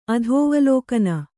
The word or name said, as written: ಅಧೋವಲೋಕನ